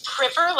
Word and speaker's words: Priver: It's Priver, like Priver. River.